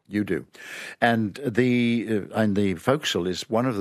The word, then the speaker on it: forecastle: Foc'sle, yes.